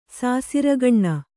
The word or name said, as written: ಸಾಸಿರ್ಗಣ್ಣ